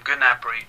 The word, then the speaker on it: Gnabry: The commentators call him Gnabry.